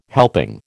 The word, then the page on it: helping: American English